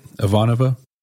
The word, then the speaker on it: Ivanova: Did I say that right? Ivanova? Yeah. Okay.